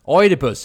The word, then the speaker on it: Oidipus: Oidipus tror jag det Oidipus säger man ju precis.